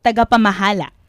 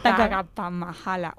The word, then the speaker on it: Tagapamahala: How would you say it in Filipino? – Tagapamahala. – Tagapamamahala. Tongue twister. – Tagapamahala. Oh, really cool.